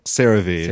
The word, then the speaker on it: CeraVe: CeraVe. – CeraVe.